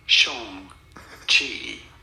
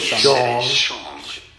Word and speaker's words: Shang-Chi: Shang-Chi. – I said it was Shang-Chi. Not Chang or Shang.